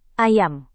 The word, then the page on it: ayam: indonesian